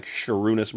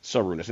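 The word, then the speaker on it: Šarūnas: There's like a serif on top of the S that I think denotes the fact that it's pronounced with a sh, like Sharunas Marcellonis isn't Sarunas.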